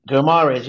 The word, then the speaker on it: Guimarães: how do you say his name? – The Brazilian. – Bruno Guimarães, is it? – Guimarães, yeah. – With Odegaard in the starting 11, Guendouzi would be nothing more than an impact sub.